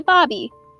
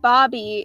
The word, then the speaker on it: Babi: Anyways, for the Egyptian side, it would be Babi. Now, you'd think Babi is spelled B-A-B-Y or like babe or something. No, it's B-A-B-I, and some people have pronounced it Babi, not Babi.